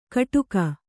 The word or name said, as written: ಕಡುಗ